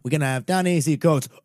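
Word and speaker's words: Ta-Nehisi Coates: Ibram Kendi and Don Easy Coats. – Yeah. – That's how you pronounce the fucking name. – Don Easy Coats.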